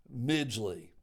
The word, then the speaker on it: Midgley: John with an H, B as in the letter, as in B, my Valentine, as in B, the movie, Midgley. Rhymes with Ridgely.